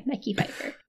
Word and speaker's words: Mekhi Phifer: Mekhi Phifer, I believe. – I believe it's Michelle Pfeiffer. – Danielle. It is not Michelle Pfeiffer. M E K H I. Mekhi Phifer.